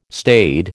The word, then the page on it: staid: American English